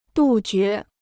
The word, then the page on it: 杜绝: dù jué